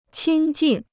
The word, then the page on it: 亲近: qīn jìn